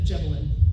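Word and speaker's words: Gébelin: C-O-U-R-T? – Yeah, like court, okay. De Gebelin.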